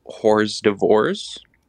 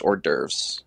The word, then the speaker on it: hors d'oeuvres: I said that they were starting with whore's divorce. Yes. which was just hors d'oeuvres.